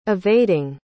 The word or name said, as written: evading